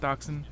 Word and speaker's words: dachshund: That's how you pronounce dachshund? Dachshund? – Yep, dachshund. – Dachshund, okay.